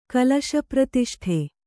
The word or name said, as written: ಕಲಶಪ್ರತಿಷ್ಠೆ